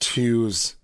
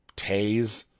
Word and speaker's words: Toews: Oh, yeah, it's pronounced twos. It's spelt toes, T-O-E-W-S, but I've heard it tase, but twos makes sense too.